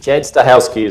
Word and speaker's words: Chad Stahelski: Que o Chad Sto, Stahelski, Stahelski, eu não sei pronunciar, na verdade, o é Shed Stahelski. Stahelski, acredito que seja assim. Chad Stahelski, isso.